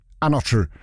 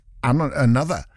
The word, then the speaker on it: Anotr: A-N-O-T-R. It's not Another. It's Anotru. Anotru. Featuring 54 Ultra. If we're saying it as it's written, Anotru. But then, you know, it's only dance music artists that do that. Go, well, I know how it's spelt, but it's pronounced Another.